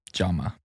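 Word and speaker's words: Jaume: I found a quote from the director, which, how do you pronounce the name? – Jama. – Okay. Like pajama.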